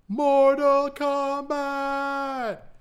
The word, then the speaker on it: Mortal Kombat: Sorry, I mispronounced that. Let me try that again. Mortal Kombat! I am contractually obligated to say it that way. – You gotta really hear the K.